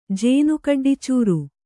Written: ಜೇನುಕಡ್ಡಿಚೂರು

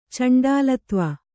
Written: ಚಂಡಾಲತ್ವ

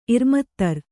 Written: ಇರ್ಮತ್ತರ್

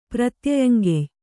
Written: ಪ್ರತ್ಯಯಂಗೆಯ್

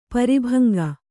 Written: ಪರಿಭಂಗ

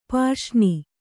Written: ಪರಸನೆ

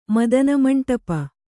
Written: ಮದನಮಂಟಪ

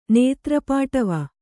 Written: ನೇತ್ರಪಾಟವ

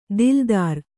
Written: ದಿಲ್ದಾರ

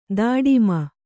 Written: ದುಡುಮಿ

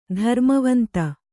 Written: ಧರ್ಮವಂತ

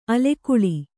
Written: ಆಲಿಗೊಳ್

